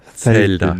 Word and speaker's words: Zelda: So ist Z wie Zelteln, oder? So spricht man das aus. Zelda.